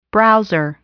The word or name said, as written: browser